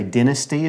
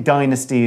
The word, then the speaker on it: dynasty: You will people s- hear uh, hear people say dynasty as well. I think dynasty is more common. I'd probably go with that pronunciation.